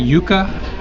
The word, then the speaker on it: yuca: Uh, we're making yuca, which I would normally have pronounced yucca fritters.